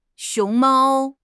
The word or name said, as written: xiong2 mao1